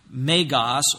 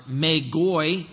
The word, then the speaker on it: Magos: The term Magi, in Greek it is pronounced Magos or Magoi in the plural, is used actually in the Septuagint, which is the Greek translation of the Hebrew Old Testament.